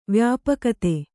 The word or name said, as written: ವ್ಯಾಪಕತೆ